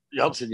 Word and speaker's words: Yelchin: What's his name? Anton Yelchin? – Yelchin, yeah. He was really good in that.